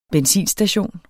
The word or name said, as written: benzinstation